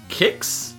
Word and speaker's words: Qix: This is Kicks. Kicks. Kicks. Q I X. Pronounced Q I X. Pronounced like the serial name.